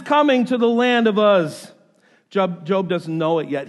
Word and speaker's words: Uz: Job lives in a land spelled U-Z. I've heard it pronounced Uz. I've heard it pronounced Ooze. Personally, I'm going with Uz because Ooze sounds icky.